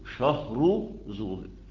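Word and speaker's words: شهرزور: فتبقى النطق الصحيح ليها شهر ايه؟ شهر زور. خلاص. بالنسبة ليها شهر، مش شهرة زي ما قدامك كده.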